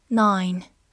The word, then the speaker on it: nine: nine，n i n e，nine，字母 i 发它本身的音 i，末尾的 e 不发音。 nine，它可以做名词、数词或者形容词，表示九或者九个，比如说。